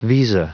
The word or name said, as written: visa